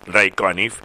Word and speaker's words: Ray Conniff: voy a pronunciar bien porque es Ray Conniff,